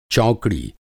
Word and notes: चौकड़ी: Hindi

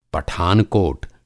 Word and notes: पठानकोट: پٹھان کوٹ; Pathankot; Hindi

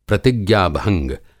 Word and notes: प्रतिज्ञा-भंग: Hindi

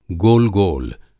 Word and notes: गोलगोल: گول گول; Hindi

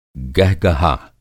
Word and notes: गहगहा: Hindi

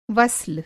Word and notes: वस्ल: وصِل

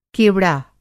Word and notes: केवड़ा: کیوڑا

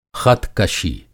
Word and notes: ख़त-कशी: خط کشی

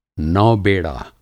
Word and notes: नौबलाध्यक्ष: Hindi

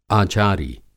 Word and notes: आचारी: Hindi

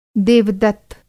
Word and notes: देवदत्त: Hindi

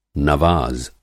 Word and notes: नवाज़: Hindi

